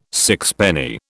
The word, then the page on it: sixpenny: British English